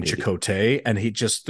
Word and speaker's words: Chekote: They spell it C-H-E-K-O-T-E, but it's definitely Chakotay, the way they pronounce it.